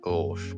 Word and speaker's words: gauche: How do you pronounce it? – Well, for me personally, I pronounce it Gauche, but like. – Gauche. – I don't know if that's the French word. – The French word for left.